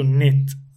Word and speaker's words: knit: Tejer en inglés se dice to knit. And it's written with K-N-I-T.